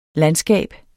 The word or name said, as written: landskab